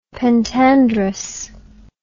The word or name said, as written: pentandrous